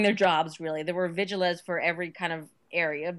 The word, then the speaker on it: vigiles: And the last thing you want in a flimsy, you know, tenement where, you know, chances are well, they'd be vigiles, if you, technical Latin, because you print the V is pronounced as a W, but the vigiles, who were basically like police/firefighters, aren't going to be coming to every building.